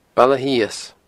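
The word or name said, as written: Baile Sios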